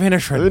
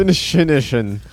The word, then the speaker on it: Inisherin: No, say it like a true Irishman. – Oh, the Banshees of Inisherin. – Inisherin.